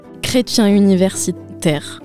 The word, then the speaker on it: chrétien universitaire: Inténtalo, chrétien. – Chrétien. – Universitaire. – Chrétien université. – Muy bien.